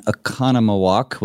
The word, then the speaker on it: Oconomowoc: Well, brother, you have a lovely church building there in Oconomowoc, Wisconsin. Am I saying that right? – Well done. Yeah, stress the second syllable. Oh, nice. – Oconomowoc, yes.